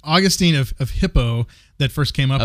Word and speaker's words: Hippo: Of H-I-P-P-O. How would you pronounce that? – I think I'd at least say hypo. – Oh, hypo? Okay. – I don't know. – Hippo? – I'm sure. – Hippo. It's probably hippo.